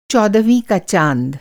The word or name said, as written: चौदहवाँ